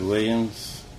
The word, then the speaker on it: Williams: Bud Williams. My last name is spelled W-I-L-L-I-A-M-S.